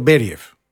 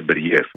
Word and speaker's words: Μπεριέφ: θα είχαμε τον Μπέριεφ, πού τονίζεται πρώτα απ' όλα για να το λέμε και σωστά. – Νομίζω το λένε Μπεριέφ.